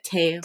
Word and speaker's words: Tao: T-A-O, Tao? How do you say that? Tao? – Tao.